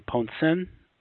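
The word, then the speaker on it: Poncins: There's a book I read called State Secrets by Leon de Ponsin, written in the 70s. – Oh, okay. – And I think I'm pronouncing that right. It's correctly Ponsin.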